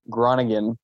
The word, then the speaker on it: Groningen: You add an R after the G, and you can say it's North Americanized, but it's Groningen.